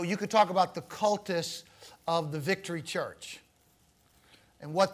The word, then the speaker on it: cultus: The focus of worship in the Old Testament is on the, I'm going to say this word, then explain it to you, okay? And spell it for you. Cultus. C-U-L-T-U-S. Have you heard that word before? What word does it sound like? – Cult, yeah.